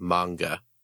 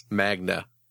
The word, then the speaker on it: manga: Oh, it's supposed to be, it's the common mispronunciation of manga. – Right. That's that makes sense. – Magna.